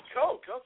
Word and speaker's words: Koch: And then how do I pronounce K-O-C-H? – Coke, just like the soft drink. – Oh, Coke, okay.